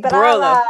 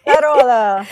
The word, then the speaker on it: Barolo: Barolo. – Exactly. Barolo. Barolo. – It's lettered to a career in food and wine. And she has an Italian tourist company, Italiana tourist company, and a Barolo wine club.